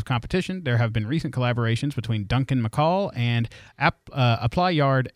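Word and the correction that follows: Appleyard: Agency. – Apple Yard. – Okay, it's spelled wrong.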